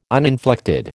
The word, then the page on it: uninflected: American English